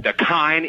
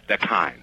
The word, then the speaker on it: Dakine: Dakine, it rhymes with spine. Dakine.